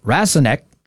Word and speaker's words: Rasonque: This new drug is called Rasonque, and it's spelled R-A-S-O-N-Q-U-E.